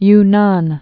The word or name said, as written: Yunnan